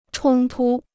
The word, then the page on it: 冲突: chōng tū